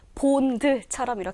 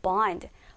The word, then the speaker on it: bond: 자, 이걸 그대로 소리내서 얘들아 읽게 된다면 마치 보이는 게 본드처럼 이렇게 보이지만 올바른 발음은요. bond. bond가 맞고요.